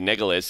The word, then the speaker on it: Nicklaus: Why is it N-I-C-K-L-A-U-S? No, I mean, everybody pronounces it Nicholas, so why don't you make it Nicholas?